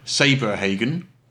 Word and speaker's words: Saberhagen: Saberhagen. It's the word saber and then the word Hagen joined into one.